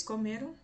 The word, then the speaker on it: comeram: Notem a pronúncia da palavra comeram. Comeram tem três pedacinhos, três sílabas. Comeram. A sílaba mais forte será a sílaba do meio.